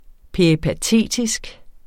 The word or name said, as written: peripatetisk